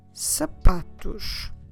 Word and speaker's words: sapatos: Sapatos. Sapatos. I'm stressing here the second to last syllable because that's a general rule in Portuguese if we have no accents.